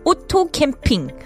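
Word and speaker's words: 오토캠핑: Now, this Otto Kimping, this is the thing that I really like because it's all English, but the pronunciation is very different from the English one. We would say auto camping or automobile camping, but in Korean it is Otto Kimping.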